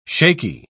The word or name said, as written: shaky